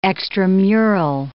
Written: extramural

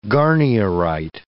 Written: garnierite

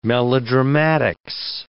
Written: melodramatics

